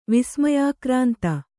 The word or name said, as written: ವಿಸ್ಮಯಾಕ್ರಾಂತ